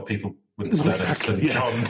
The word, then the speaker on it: St. John: It's STJOHN. That's pronounced St. John.